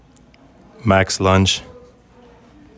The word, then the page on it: Max Lantzsch: MAX LUNCH